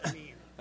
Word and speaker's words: Amin: It is the Arabic language, and we say Amin. – Oh, okay. All right. – And my name is Amin.